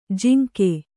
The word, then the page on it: ಜಾಂಗು: Kannada